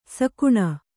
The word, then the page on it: ಸೆಗಣಿ: segaṇi